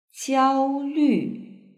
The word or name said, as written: jiaolv